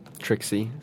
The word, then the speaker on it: TRXYE: Also pronounced as what? – Trixie, by some.